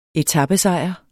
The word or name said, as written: etapesejr